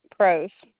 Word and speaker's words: Prose: Joyce Prose. – And what was that last name, ma'am? – P-R-O-S-E.